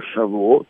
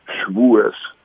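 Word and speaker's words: Шавуот (שבועות): Но э, 25-го первый день действительно великого праздника Матан Зоратейну, э, праздник называется Шавуот, или по-ашкенадски Швуэс – его многие произносят.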